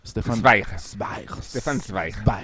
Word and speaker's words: Stefan Zweig: Que del cual tenemos novedad también desde hace poco, que es Stefan Zweig, no sé, sí. nunca hemos unificado la pronunciación, ¿verdad? – Stefan Zweig. Así es.